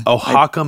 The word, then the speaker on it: Oaxaca: If you talk to some actual like practicing ayahuasca shamans, but I do know that with salvia in is it called how do you pronounce that Mexican? – Oaxaca, Mexico.